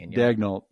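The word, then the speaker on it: Daigneault: I always pronounce his name wrong. Daniel. – Dagnall. Yeah.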